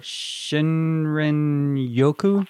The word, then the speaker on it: Shinrin-yoku: They call it Shinrin-yoku. – Yeah, that sounds great to me. – So can you talk about that and what they're doing in Japan and what you discovered? – Sure. So Shinrin-yoku is Japanese for forest bathing.